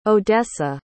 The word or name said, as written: odessa